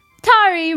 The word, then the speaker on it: Tari: Tari rhymes with Jabari.